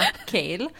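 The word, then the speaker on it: Chaol: Kale. För att Kale är tydligen hur han uttalar sitt namn.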